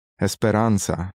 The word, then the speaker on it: Esperanza: Esperanza.